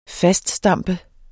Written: faststampe

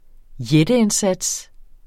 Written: jætteindsats

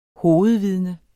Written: hovedvidne

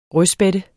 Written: rødspætte

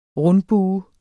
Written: RUNDBUER

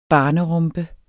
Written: BARNERUMPER

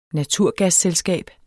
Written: naturgasselskab

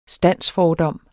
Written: standsfordom

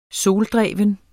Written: soldreven

